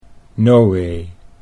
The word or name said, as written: Nowee